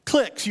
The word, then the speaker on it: clique: And then there are cliques. I actually said that right, you know. Cliques, you would probably know. The word unique, U-N-I-Q-U-E, is unique. The word clique is C-L-I-Q-U-E. It's clique.